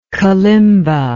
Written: kalimba

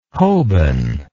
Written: hoban

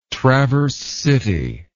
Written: traverse city

